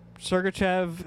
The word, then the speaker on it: Sergachev: Sergachev has a hell of a lot of potential.